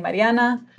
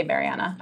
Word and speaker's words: Mariana: Yeah, to start saying Mariana. And it's funny because no one pronounced my name right before when I would say Mariana. I would like go to Starbucks and people would be like, Meredith.